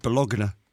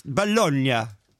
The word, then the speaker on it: Bologna: You know why? Because spaghetti bolognese. Bologna. It's Bologna.